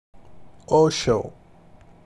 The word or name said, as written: Osho